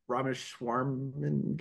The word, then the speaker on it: Ramaswamy: I mean, it could be that Ramaswamy guy, the name I've never figured out how to pronounce.